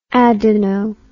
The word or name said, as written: adeno